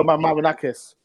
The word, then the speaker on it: Marinakis: Uh, Evangel Marinel, is it pronounced? I'm useless. – Evangel Marinakis.